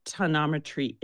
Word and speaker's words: tonometry: And you monitor it through something called tonometry. Did I get the correct pronunciation of it? – Yes. – Tonometry. – Yes.